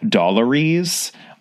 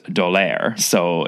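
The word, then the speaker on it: D'Aulaire: I always pronounced as Dallaire's, but I only just recently realized that cannot be the way that it's pronounced. And by recently, I kind of mean today, because I realized I was going to have to say the name of the book aloud. And I was like, wait a second, that cannot be how that's pronounced, because it's written by a French couple whose last name is Dallaire. So it clearly has to be Dallaire's Book of Greek Myths.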